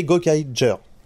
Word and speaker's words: Gokaiger: Si vous lisez les katakana, ça s'écrit Ja, ça s'écrit pas Gar. Et en plus, c'est des Rangers, c'est pas des Rangers. Donc, c'est Gokaiger.